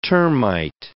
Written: termite

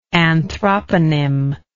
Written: anthroponymic

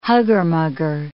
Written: hugger-mugger

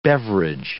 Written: beverage

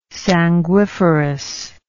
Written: sanguiferous

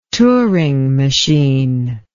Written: Turing machine

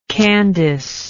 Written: candice

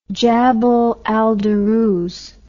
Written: jabal al duruz

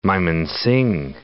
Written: Mymensingh

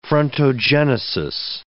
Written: frontogenesis

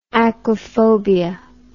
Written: aquaphobia

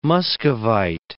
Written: muscovite

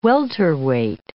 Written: welterweight

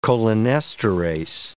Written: cholinesterase

